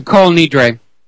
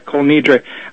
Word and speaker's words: Kol Nidre: It's a declaration called the Kol, and it's spelled N-I-D-R-E. That's the second word. The Kol Nidre. – Yeah, Kol Nidre.